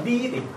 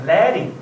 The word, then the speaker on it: leading: 这个词不念 leading 啊，应该念 leading 啊。